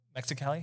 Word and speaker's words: Mexicali: Mexicali, is that how you pronounce yeah.